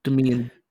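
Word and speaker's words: Demián: Demian. – Demian Bichir? Don't know how to pronounce that. – Yeah. I would actually pronounce as Demian. Demian.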